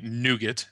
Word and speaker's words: nougat: There are a couple of notable exceptions like nougat. – Yeah, right. I was going to say nougat, as Americans would call it, because we're incapable of a silent T.